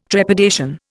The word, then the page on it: trepidation: Indian